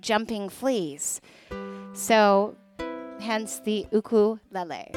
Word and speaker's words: ukulele: And that is how you pronounce it, ukulele. I know people say ukulele, but it's ukulele.